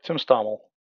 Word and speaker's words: Tim Stommel: Yep, Tim Stommel.